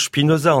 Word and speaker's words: Spinoza: que l'on prononce Spinoza en allemand et en yiddish, en tout cas, c'est comme cela que mes maîtres yiddishisants le prononçaient.